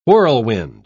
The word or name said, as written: whirlwind